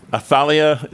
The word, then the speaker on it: Athaliah: Athaliah is how you would pronounce her.